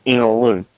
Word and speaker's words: Innaloo: that's 6 hours away from us, and the suburb's called Innaloo, and it's spelled In-a-loo.